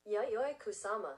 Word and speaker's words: Yayoi Kusama: Yayoi Kusama.